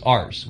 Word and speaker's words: arse: It's actually spelled as arse, which is A-R-S-E. So, I'm going to kick your arse. Arse, but it's a silent R.